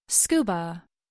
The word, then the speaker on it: SCUBA: We do not say each individual letter. We pronounce the acronym as a whole, SCUBA.